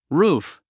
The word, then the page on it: roof: rúːf; ルーフ